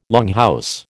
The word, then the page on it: longhouse: American English